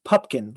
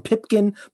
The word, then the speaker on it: Pupkin: people often misspell it and mispronounce it. And like, his name is Pupkin. Like, why do they misspell it and mispronounce it? like P U P K I N. Uh, but they do. they do misspell it and mispronounce it throughout the whole movie. They call him Pipkin, Pumpkin.